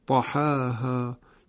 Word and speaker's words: طحاها: و همینطور آیه ششم همین سوره شمس طحاها که باز اینجا هم بدون اماله تلفظ میشه.